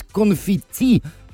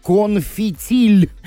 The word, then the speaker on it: конфетти: В 27 лет узнал, что правильно говорить «конфетти», а не «конфетиль».